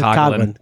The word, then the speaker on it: Coughlin: How do you say? Coughlin. – Coughlin.